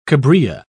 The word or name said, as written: cabrilla